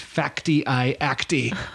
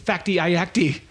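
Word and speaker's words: Fakty i Akty: It's Facti I Acti. F-A-K-T-Y-I-A-K. – I like that. – Facti I Acti.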